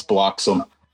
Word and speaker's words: Bloxham: It's Bloxham. You don't have to emphasize the H.